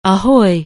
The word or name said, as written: ahoy